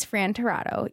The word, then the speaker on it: Tirado: His name is Fran Tirado.